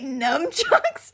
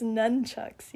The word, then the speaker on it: nunchucks: N-U-N or N-U-M? We're both wrong. – None. Nunchaku. – Okay, so I've been saying it wrong for... – I was like, wait, numchucks? – For 20 plus years? – No, it's nunchucks, yeah.